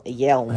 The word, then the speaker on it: Yelm: In Yelm, Washington. – Yelm? – Yelm. – How do you spell that? – Y-E-L-M. – Yelm. – Like yell, but with an M?